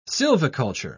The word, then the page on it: sylviculture: British English